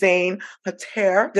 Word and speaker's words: Zein Khater: Her name is Zein Khater. Did I say it right, Zein? – Yeah, it's Zein Khater. Yeah. – Zein Khater.